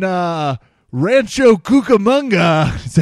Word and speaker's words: Rancho Cucamonga: Let's go out to Jan, who's in Rancho Cucamonga. Am I pronouncing that right? Yes, Jan, that's right.